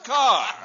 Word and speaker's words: Kerr: And I include Douglas Fairbanks Jr. and Deborah Kerr, pronounced Carr.